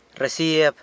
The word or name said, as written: rresieb